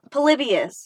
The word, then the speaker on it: Polybius: Polybius.